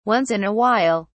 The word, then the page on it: Once in a while: British English